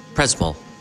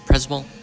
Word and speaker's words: Przemyśl: And could you pronounce exactly where we? It's Przemyśl. Przemyśl. This town where we are. – This Przemyśl. – I'm sorry, Przemyśl. I pronounce it completely different.